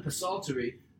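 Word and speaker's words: Psaltery: And then Solomon Pasaltery, which literally was written to rhyme with adultery.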